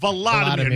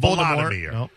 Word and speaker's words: Volodymyr: Did I mispronounce the uh, yes. Vladimir? Yeah, we all yes, did. That's why you said it. How yeah. are you saying Volodymyr. it? Volodymyr, not Vladimir.